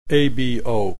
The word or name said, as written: ABO